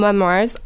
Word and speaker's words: memoirs: And just be careful of the pronunciation. It's spelled M-E-M-O-I-R-S, but it's a French word, actually, so we say memoir.